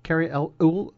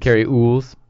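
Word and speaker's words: Cary Elwes: Carrie Elvis? – I don't know who you're talking about.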